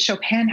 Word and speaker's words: Chopin: So don't say the N, it's Anne. – Okay. – Chopin. And I know that's hard for non-native speakers to make that sound, but that's the sound.